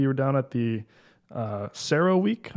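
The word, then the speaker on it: CERAWeek: Yeah, Sierra Week. So it's an acronym. We love our acronyms in chemicals. Yeah. This is for Cambridge Energy Research Associates.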